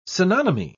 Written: synonymy

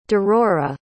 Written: derora

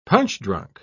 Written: punch-drunk

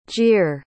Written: gere

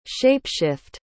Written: shape-shift

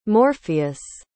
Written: morpheus

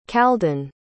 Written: khaldun

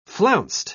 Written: flounced

